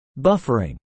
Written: buffering

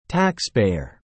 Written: taxpayer

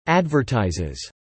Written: advertises

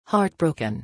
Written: heartbroken

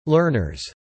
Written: learners

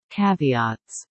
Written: caveats

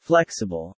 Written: flexible

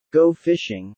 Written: go fishing

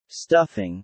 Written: stuffing

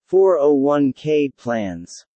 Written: 401k plans

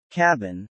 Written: cabin

